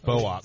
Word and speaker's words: Boox: It's pronounced Boox.